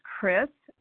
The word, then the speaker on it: Kris: And I also, my name is Chris. I spell it K-R-I-S.